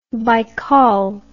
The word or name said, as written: Baykal